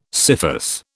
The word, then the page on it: scyphus: British English